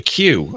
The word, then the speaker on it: queue: It's called a queue over here. Q-U-E-U-E. that's how you spell it. A bit of a word, isn't it? Queue.